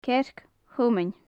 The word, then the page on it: cearc-thomain: gaelic